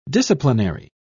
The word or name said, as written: disciplinary